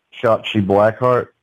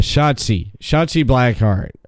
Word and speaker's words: Shotzi Blackheart: Shotzi Blackheart. – Yeah, Shotzi Blackheart.